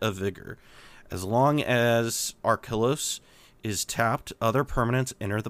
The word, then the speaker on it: Archelos: but is very very good is Archelos? Did I say that right? Archelos. – Archelos. I, I, I would say Archelos.